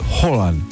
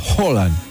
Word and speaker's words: Haaland: Ah, escuché a noruegos decir que no es Haaland, es Haaland. Con O. Se parece a una O tipo aspirada, Haaland.